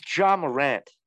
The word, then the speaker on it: Ja Morant: What did you just pronounce? – Ja Morant. – What are you, French? I believe it's Ja Morant.